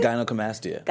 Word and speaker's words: gynecomastia: How do you pronounce it? – Gynecomastia.